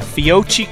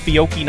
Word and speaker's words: Fiocchi: We got Fiocchi coming up. It's pronounced Fiocchi, not Fiocchi.